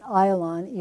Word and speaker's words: Ayalon: It's pronounced Ayalon, even though most texts are going to say Ajalon.